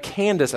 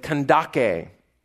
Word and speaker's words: Kandake: Instead, that all fell on the queen mother, the Candace, as we read, or it was actually pronounced the Kandake.